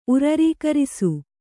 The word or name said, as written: ಉರರೀಕರಿಸು